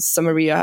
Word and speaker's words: Samaria: dass es wirklich Judäa und Samaria heißt.